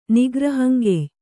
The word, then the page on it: ನಿಗ್ರಹಂಗೆಯ್: nigrahaŋgey